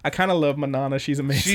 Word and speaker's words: mañana: Manana. Is it mañana? You said mañana.